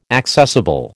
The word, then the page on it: accessible: American English